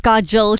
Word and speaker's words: Kajal: So Pannal Akhani, by the way, my name is Kajal, K-A-J-A-L. You said it right the first time, so just keep it as that.